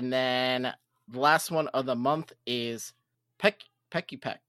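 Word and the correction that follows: Pikipek: No? I said it wrong? Picky Peck? – There you go. Picky Peck.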